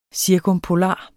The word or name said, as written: cirkumpolar